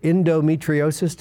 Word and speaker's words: endometriosis: endometriosis, did I pronounce that correctly? – Yes, you did.